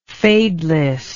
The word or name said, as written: fadelessly